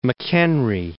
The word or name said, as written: Fort McHenry National Monument